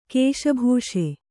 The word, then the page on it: ಕಸಿಬಿಸಿ: Kannada